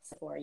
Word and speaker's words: Sephora: Am I pronouncing this right? Sephora. – Sephora, yeah.